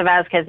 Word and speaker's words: Vazquez: That's spelled V-A-Z-Q-U-E-Z is my last name. And then Lisa Vasquez Music for all my social media stuff.